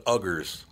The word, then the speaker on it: Uyghur: So the Muslims in China are called, that's spelled U-I or U-Y-G-H-U-R-S. Okay? U-I-G-H-U-R-S. You know how it's pronounced? – No. – Uyghur.